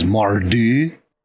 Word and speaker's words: Mordhau: I think the proper pronunciation is Mardu. I think. Mordhau.